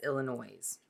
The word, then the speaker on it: Illinois: I believe that's Illinois. – Okay. You know the S is silent, right? – Illinois. – There you go.